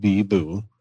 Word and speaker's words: Bibo: And there's Bibu, B-I-B-O, pronounced Bibu.